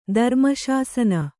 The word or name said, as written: ಧರ್ಮಶಾಸನ